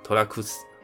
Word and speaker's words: 寅楠: 那衍男这两个字念作托拉库斯。所以接下来我们就直接称呼他的名字，托拉库斯。